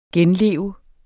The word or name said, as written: genleve